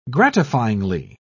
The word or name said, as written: gratifyingly